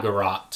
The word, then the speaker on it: garrote: Is that what, how you say it? Garrote? – It's called a garrote.